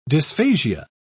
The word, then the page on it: dysphasia: British English